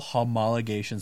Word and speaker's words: homologation: These are all homologation specials.